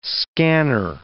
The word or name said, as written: scanner